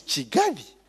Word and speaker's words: Kigali: Pronunciation, it is Kigali. I say, Kigali. Okay? Because there's a K, so they don't understand why you put yes, a Y or a C-H-I. To them, it's a K.